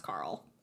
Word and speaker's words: Karel: So Adele's husband, Carl, it's spelled K-A-R-E-L, but I feel like that's Carl.